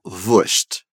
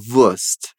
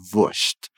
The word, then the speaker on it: Wurst: E percebe que ela falou Wurst, Wurst. Essa é uma variação possível da pronúncia. Você também pode ouvir por aí Wurst, Wurst, sem fazer esse som tão chiado, né? Wurst.